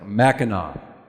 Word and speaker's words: Mackinac: Now, I have to admit, a couple days ago, I made reference to the Mackinac Bridge. And several of you have kindly corrected me. Thank you. So I'll make sure and say it correctly this morning. Mackinac.